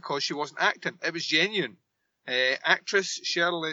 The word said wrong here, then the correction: Sherilyn: I can't say her name, sherilyn Fenn um, thought that the entire Miss Twin Peaks plotline was dated and sexist.